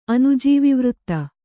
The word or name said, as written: ಅನುಜೀವಿವೃತ್ತ